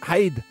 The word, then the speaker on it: heid: Head is hide. – H-E-I-D, they spell it. Haid. Yeah. – Haid.